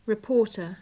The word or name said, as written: reporter